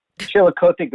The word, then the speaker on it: Chillicothe: Chillicothe Gazette.